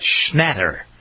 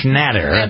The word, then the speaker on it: Schnatter: That's how you pronounce his name, right? You worked at great length to perfect that. Schnatter. John Schnatter. – Emphasis on the N since he likes that Schnatter.